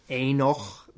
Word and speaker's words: Henoch: En van deze heeft ook Enoch. Dat is Henoch. Maar de H is een stomme letter. Dus die spreek je, niet, spreek je dan soms niet uit.